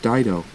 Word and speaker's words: Dido: "Did I ever tell you my real name was pronounced Dido? – Long ago, some idiot mispronounced it, and everyone, including my own daughter, called me Ditto ever since," he said.